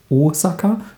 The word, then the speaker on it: Osaka: Äh, also es heißt, falls ihr jetzt euch gefragt habt, warum, es heißt Hokkaido, dann heißt es Osaka, also ein langes O und Kara ist kurz, ist nicht Kara oder sonst irgendwas, sondern Karate.